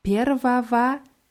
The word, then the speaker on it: Первого: Und dieses Первого wird mit Ws am Ende mit zwei Ws ausgesprochen, aber geschrieben wird es mit einem G zwischen zwei Ws.